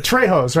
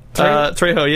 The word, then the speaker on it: Trejo: he pronounced it Trejos, right? – Trejo, yeah.